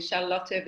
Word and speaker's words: Charlotte: And I love it when you call me Charlotte because I'm from Germany and that's the way it's pronounced and it feels like home.